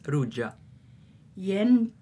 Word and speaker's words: ruĝaj: The ending is just the same as for nouns, spelt J, pronounced like English Y. – Jen ruĝa libro. La libro estas ruĝa. – Jen du ruĝaj libroj.